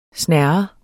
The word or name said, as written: snerren